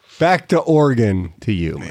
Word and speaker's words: Oregon: And Oregon's like, nobody cares. No one cares. – It's pronounced Oregon. So Oregon.